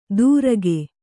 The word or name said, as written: ದುರಗೆ